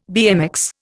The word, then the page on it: BMX: Indian